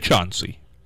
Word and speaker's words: Chauncey: Like, if you live in Athens, Ohio, and you pronounce the word, the name of the city, C-H-A-U-N-C-E-Y, Chauncey, nobody will know what you're talking about, or they'll laugh you off the place. It's Chauncey.